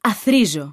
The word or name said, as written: αθροίζω